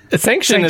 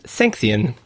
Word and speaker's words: Sanxion: Sanction is how us Americans say it, but he says sanction. – S-A-N-X-I-O-N.